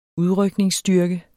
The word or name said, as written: udrykningsstyrke